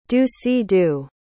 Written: do-si-do

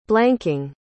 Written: blanking

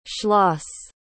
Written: schloss

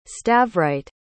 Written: stavrite

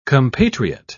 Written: compatriot

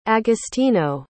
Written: agostino

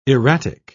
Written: erratic

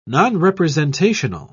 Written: nonrepresentational

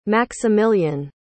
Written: maximillian

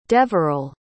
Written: deverel

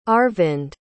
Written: Arvind

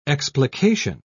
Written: explication

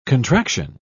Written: contraction

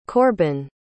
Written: korbin

